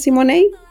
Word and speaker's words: Simoney: Eh, recordemos tus redes sociales para que busquen la nueva música que tú tienes, Simonei. Así es, mira, en todos lados soy arroba Simonei oficial. Arroba Simonei oficial. – Simonei se escribe como si money, o sea, sí dinero.